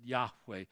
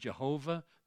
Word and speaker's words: Yahweh: We pronounce it Yahweh. His name isn't Jehovah. There's no J or V in the Hebrew alphabet.